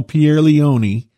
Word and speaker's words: Pierleoni: That's how you say it, Cardinal Pierleoni, in usurping the dignity of Pope.